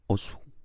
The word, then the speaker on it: Osu: Pero la única frase que tenía en mi cabeza era OSU. Se escribe O-S-U. OSU. Pero se pronuncia OS. Y para los que practican Jiu-Jitsu se escribe O-S-S. y se pronuncia igual.